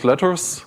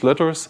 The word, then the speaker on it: LTTRS: Der heißt, spricht man aus Letters, also dieses LTTRS ist der Produktname, ist Letters.